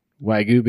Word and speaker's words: Wagyu: And I assume I'm saying that right. Wagyu beef? – Yep, that's right.